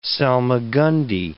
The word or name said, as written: salmagundi